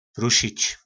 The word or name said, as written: prusic